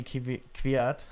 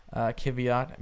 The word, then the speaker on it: Kvyat: Danny Kvyat. That's how you say his name. Kvyat, maybe, if that's not how you say his name.